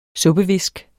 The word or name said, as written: suppevisk